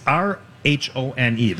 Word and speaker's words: Rhone: Uh, it's it's a chartered river cruise, and I, I don't know how to pronounce this word. Which is R H O N E. The Rhone. Rhone. The, the Rhone.